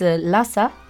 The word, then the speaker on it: Lhasa: Y vamos a pasar a Rincones del Mundo, que hoy vamos a ver un sitio muy bonito del Tíbet, que es Lhasa.